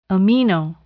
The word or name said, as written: amino